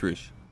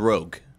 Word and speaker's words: Rouge: My whole childhood, I thought it was Rogue, like you know, with a hard G.